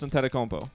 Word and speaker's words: Antetokounmpo: and Kostas Antetokounmpo. Did I say that right for you guys? The last name? – Yeah. – Antetokounmpo. No, but you said it differently than when you say Giannis's name.